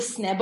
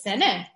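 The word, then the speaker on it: sene (סנה): The sne bo'ed, who says sene? But that is the proper way to say it.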